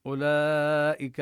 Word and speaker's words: أولئك: أولئك هنا الواو وإن كان رسمت لكن ليس لها تأثير في النطق، لا نقول أولئك، إنما وضعوا عليها هذه الدائرة الصغيرة لبيان أنها تكتب لكن لا تلفظ.